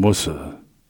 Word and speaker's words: Worcestershire: Worcester or Worcestershire is pronounced Worcester. I mean, people say, "Would you like some Worcestershire sauce?" And you go, "It's Worcester sauce." – Yeah, Worcestershire. – I mean, you just leave off the shear anyway.